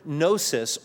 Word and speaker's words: gnosis: But when you think of that term Gnostic, it's from the Greek word gnosis or gnosis. The G is silent. It's G-N-O-S-I-S.